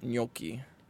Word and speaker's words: gnocchi: Chicken gnocchi.